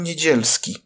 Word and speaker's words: Niedzielski: Our health minister's name is pronounced Adam Niedzielski.